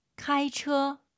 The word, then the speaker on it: 开车: 开车. – 자동차 발음하실 때 처, 처 문맥상 알아듣겠죠. 안 예뻐요. 처 두 글자라 생각하시고 처 운전하다 한 번 더.